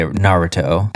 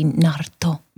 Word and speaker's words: Naruto: I think the most fans say Naruto. I feel like that that's like the most common I mean, of- pronunciation. Of, course, in Japanese, it would just be Naruto.